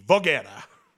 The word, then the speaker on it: Voghera: Voghera. Yeah, Voghera. – Yeah, if you can't pronounce it, I sure can't. – Yeah, Voghera. It's a hard G. Voghera.